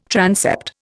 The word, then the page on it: transept: Indian